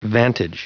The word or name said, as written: vantage